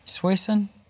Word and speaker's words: Suisun: Is it Suisun City, or did we not talk about this one? Do you have any clue? Suisun? – Sure.